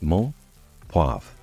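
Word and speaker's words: menthe poivrée: Peppermint in French is pronounced mon poivre, and spelled mon, M-E-N-T-H-E, poivre, P-O-I-V-R-E-E.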